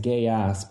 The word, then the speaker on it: Geass: I know it's pronounced gay ass, but it's still really good.